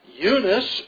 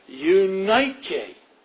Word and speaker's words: Eunice: His mother's name was Eunice, or as the Greeks would pronounce it, Eunike, Eunike.